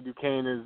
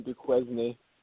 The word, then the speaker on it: Duquesne: I had no idea how to pronounce that. I was saying Duquesne. – Well, that's how it looks. So I mean, exactly. – I don't know how you get Duquesne, you know, how it's spelled, but I guess that's how it's pronounced. – I'm thinking the silent S.